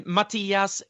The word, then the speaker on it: Mattias: Am I saying your name correctly? – Thank you so much, Xavier. Uh, I'm Mattias.